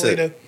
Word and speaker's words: Ippolito: See, is it pronounced Ippolito or Ippolito? – It is. No, that's Ippolito. – Is that Greek? – No, it's Italian.